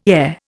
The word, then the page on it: heir: Indian